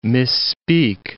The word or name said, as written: misspeak